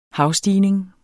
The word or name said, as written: havstigning